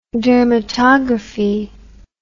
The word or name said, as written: dermatographic